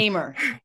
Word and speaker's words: Hamer: Today, my guest, also a podcaster, author, HR leader and speaker, please welcome Kim Harmon. Did I say it correctly? Your surname? – Hamer. – Hamer. – Close. Hamer. Close. Yes, yes, close.